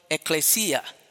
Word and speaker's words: ekklesia: The word church as used in the New Testament is from the Greek word ekklesia. Hallelujah. Ekklesia, which is spelled E-K-K-L-E-S-I-A.